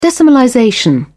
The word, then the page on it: decimalization: British English